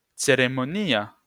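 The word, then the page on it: ceremonia: Esperanto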